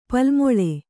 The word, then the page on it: ಪಲ್ಮೊಳೆ: Kannada